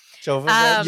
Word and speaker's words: Jovovich: Jovovich.